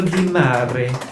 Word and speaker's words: Di Mare: Antonio Di Mare. Like a seat.